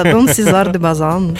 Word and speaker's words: Дон Сезар де Базан: Да, тренировалась, правильно сказала. – Скажи красиво, да, Дон Сезар де Базан.